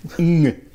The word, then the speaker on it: Ng: Charles Ng. – Charles Ng. – Ng. Ng. Det är ett vanligt Ng-ljud där.